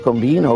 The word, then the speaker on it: Combin: venne clamorosamente rovesciato da tre gol di Nestor Combino, o Combin, per chi lo pronunciava la francese, e tre gol bellissimi, tre gol di possesso, tre gol furiosi.